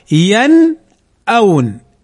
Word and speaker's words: ينأون: لو نظرنا إليها نجدها ساكنة، ين، لم نقل ينو أو ينا أو يني، إنما ينأون، ينأون. ماذا نفعل؟ نظهرها ولا نضغمها أو نخفيها أو نقلبها، وإنما أو نقلبها، وإنما نقول ينأون، ينأون.